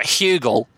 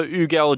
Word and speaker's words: Hugel: You tell me it's not Hugel. That H is not pronounced. I don't think so. I mean, I don't speak French, but I've heard some French people pronounce it. That's sort of how they're going with it. So Ugel Gentil.